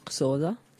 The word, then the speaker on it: Xhosa: Sì, che strano, questo in lingua Xhosa, si pronuncia con un click, che è una cosa, Non è un errore tecnico. – non è un errore tecnico, si pronuncia con un click all'inizio.